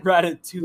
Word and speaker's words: Ratatouille: Ratatouille. – Whatever. – You don't pronounce the L. – The double L is not pronounced in French.